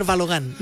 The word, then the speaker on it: Balogun: muy mal le decía Balogun, porque yo creía que así se pronunciaba, digamos, el norteamericano, entonces termina en U, debe ser Balogun. No, es Balogun.